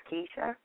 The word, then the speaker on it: Kisha: My name is Keisha. – Can you spell it? – K-I-S-H-A.